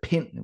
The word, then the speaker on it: Pind: which was a Pind, which is P-I-N-D, but with a silent D.